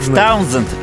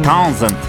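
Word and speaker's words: Townshend: Он сказал Пит Таунзенд. С, но больше как З слышно. Таунзенд.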